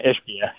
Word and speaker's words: Ishbia: Is it Ishbia or Ishbia? – Yeah, Ishbia. Yeah, Ishbia, who decided he'd rather go have a stake in the Chicago White Sox than the Twins, which is really interesting.